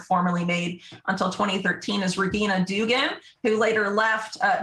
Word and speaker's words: Regina: Uh, uh, Regina. Uh, it's a hard G, but it's spelled like Regina mm.